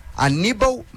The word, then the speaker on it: Hannibal: E aí eu fui ouvir a pronúncia em árabe, né? Na língua da Tunísia. É Hannibal. Hannibal Mejbri. Essa é a pronúncia dele.